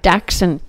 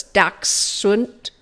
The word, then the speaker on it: Dachshund: Dachshund. – Yeah, it's badger dog in German, so it's Dachshund. – Oh, yeah. God. Okay, so how did you happen to fall upon the dachshund?